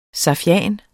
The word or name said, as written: safian